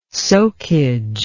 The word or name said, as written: soakage